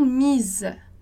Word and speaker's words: mises: Então, lá no singular, elles se sont mises, e no plural, elles se sont mises. Então, tem um E ali, discreto, no final, que você tem que pronunciar.